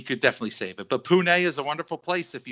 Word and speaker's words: Pune: Pune is how you pronounce it.